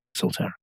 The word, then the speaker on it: Solterra: Solterra, thanks.